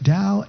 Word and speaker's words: Tao: In fact, Tao itself, which is spelled T-A-O, but pronounced Tao, means the way.